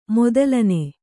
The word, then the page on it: ಮೊದಲನೆ: modalane